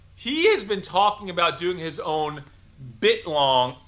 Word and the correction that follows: biltong: Biltong. – How do you say it? – Biltong. – Biltong? Is that what you said? Yes.